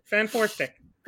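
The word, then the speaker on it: Fant4stic: I'm looking at you, Fanfortastic. Fanfortastic. I don't even know how to say it. Fan with that four stick. In the middle. Fanfortastic. – Yeah, Fanfortastic.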